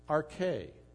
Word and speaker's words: arche: It's spelled we'd spell it in English A-R-C-H-E. Not Archie, but arche. And you could hear in the English the word architect. Because that's where we derive our word, architect, from this Greek word, arche, because it describes one who begins, or the origin, the source.